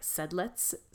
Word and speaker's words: Sedlec: And it is a small chapel located in Sedlitz, which is S-E-D-L-E-C, like Sedlec, but I looked up the pronunciation and it's like Sedlitz, so that's pretty.